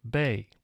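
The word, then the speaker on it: bae: That's B-A-E, pronounced Bay.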